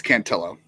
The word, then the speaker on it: Cantillo: Cantillo is another one of those guys, or Cantillo. How do you say that, actually? – It's Cantillo.